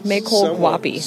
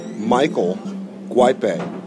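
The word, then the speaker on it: Mayckol Guaipe: Maykole Guapi. – Somewhat close. It's actually Michael Guipe. – Not close. – In my defense, his name is spelled M-A-Y-C-K-O-L.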